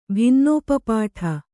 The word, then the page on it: ಭಿನ್ನೋಪಪಾಠ: Kannada